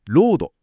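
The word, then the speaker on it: lode: あと発音が全く同じで、ロードっていう鉱脈っていう意味の単語があるので、これはちょっと注意ですね。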